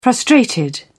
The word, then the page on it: frustrated: British English